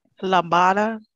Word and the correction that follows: Lambda: Lambada? It's not Lambda? – Lambda? I don't have my glasses on. Let me spell it. Hold on. – Lambda. – Lambda. – Lambda. Yeah, because they're all Greek alphabets.